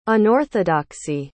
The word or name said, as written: unorthodoxy